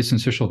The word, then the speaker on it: syncytial: Is it respiratory syncytial? Well, how do I say this? – Yeah, that's very good. Yeah, respiratory syncytial virus.